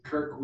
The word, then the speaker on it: Kirk: My name is Kirk Wiemet, spelled K-I-R-K. Last name is O-U-I-M-E-T.